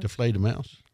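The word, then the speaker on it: Die Fledermaus: Die Fledermans? Is that how you say that? – Deflatermouse.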